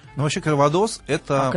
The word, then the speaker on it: кальвадос: Ну да, ну, во Франции, во французских словах на последнее гласное делается ударение. Кальвадос, коньяк.